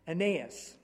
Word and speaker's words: Aeneas: How do you say his name? – I said Aeneas. – Aeneas. Okay, I like that better. And, I said, that's how we should do it.